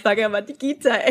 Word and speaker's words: Bhagavad Gita: I'm just very proud that I can pronounce Bhagavad Gita exactly.